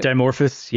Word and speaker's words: Dimorphos: It launched this asteroid at 14,000 miles per hour on purpose into the Dimorphos. Is that how you say it? – Dimorphos.